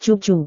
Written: cucu

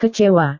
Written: kecewa

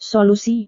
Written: solusi

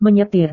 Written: menyetir

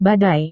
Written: badai

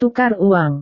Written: tukar uang

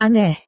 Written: aneh

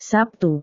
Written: sabtu

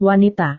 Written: wanita

